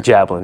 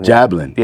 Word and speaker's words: Jablin: Jablin. Jablin. Yeah. Jablin. Like jabbing.